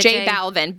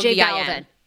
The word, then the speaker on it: J Balvin: Tell me again. How do I say it? – J Balvin. B-A-L-V-I-N. – J Balvin.